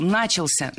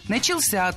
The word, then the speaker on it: начался: Много раз прозвучал глагол «начался», но это неверно. Правильный вариант – «начался», только так. Ударение в этом слове падает на возвратную часть «ся».